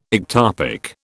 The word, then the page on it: ectopic: British English